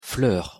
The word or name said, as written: fleur